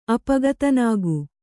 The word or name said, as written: ಅಪಗತನಾಗು